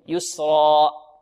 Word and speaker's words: يسرى: طيب نقف عليها بمقدار حركتين، أكثر من الحركة من حركتين لا نقف عليها بأكثر من حركتين، ولا نختمها بهمزة، نقول يسرى، يسرى. نبهنا عن هذا أو لم ننبه عنه؟ نبهنا. يسرى خطأ، يسرى، يسرى.